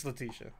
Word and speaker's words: Letitia: Isn't it Letitia right? – It's Letitia. I've been mispronounced.